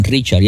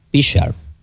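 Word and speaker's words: Prichard: Padre, también nos unimos a, ¿verdad?, orando para que Dios le dé paz y fortaleza a la familia del exboxeador Pichard Colón. Déjame pronunciar bien porque algunos dicen Richard y es Pichard con P.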